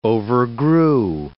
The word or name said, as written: overgrew